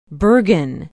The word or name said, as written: bergen